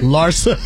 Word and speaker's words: Larsa: Larsa. That's how you say her name.